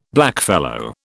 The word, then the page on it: blackfellow: British English